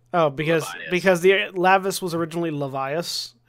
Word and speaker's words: Lavias: I just changed the spelling part way through. It's always been pronounced Lavias.